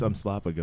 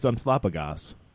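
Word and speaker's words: Umslopogaas: Umasopolopoulos I am really sorry for fucking that name up, but it is hard with an English tongue to pronounce it, and they do not. – Oh, really? It's Umslopogos. – I don't think they ever they say it in the movie. – Yeah, it's Umslopogos.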